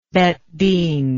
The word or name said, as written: beth din